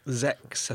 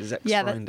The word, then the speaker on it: Sexfreunde: Zex, I think. – Zex. – It's officially Sexfreunde, yeah. – That sounds better. – Zex in Germany. – Yeah, I was just in Germany, but clearly that hasn't helped me with my pronunciation. – I know that is with a Z.